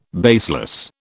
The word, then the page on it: baseless: British English